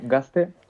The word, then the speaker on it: Gaztel: Sí, Gaztel, lo decimos en euskera, se pronuncia con S.